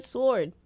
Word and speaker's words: sword: It's not pronounced with the W. but only that song pronounces it with a W. And guess where I learned how to pronounce the word sword? – Aladdin.